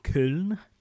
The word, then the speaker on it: Köln: It takes place in every year in Cologne to us English people. Köln to the Germans. It's spelled K. Accented O-L-N, as the Starbucks mug behind you, young Tom Parry, will attest to.